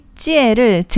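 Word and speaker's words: 찌에를 (节): 그러니까 광군제 제가 무슨 축제 제가 아니고 어, 중국어의 찌에를 제라고 그냥 읽은 거예요.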